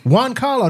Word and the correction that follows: Giancarlo: that's how you say it. – It's pronounced something. He like makes sure. – Giancarlo. Yes. Giancarlo.